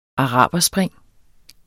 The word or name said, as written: araberspring